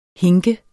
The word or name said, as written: hinke